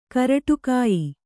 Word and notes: ಕರಟುಕಾಯಿ: karaṭukāyi

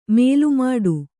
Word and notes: ಮೇಲ್ಮಟ್ಟ: mēlmaṭṭa

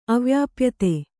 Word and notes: ಅವ್ಯಾಪ್ಯತೆ: avyāpyate

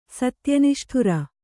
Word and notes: ಸತ್ಯನಿಷ್ಠುರ: satya niṣṭhura

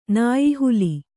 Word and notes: ನಾಯಿಹಾಲೆ: nāyi hāle

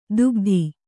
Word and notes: ದುಗ್ಧಿ: dugdhi

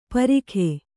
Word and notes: ಪರಿಖೆ: kannada

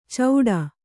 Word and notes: ಚೌಡ: cauḍa; kannada

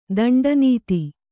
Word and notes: ದಂಡನಾಥ: daṇḍa nātha

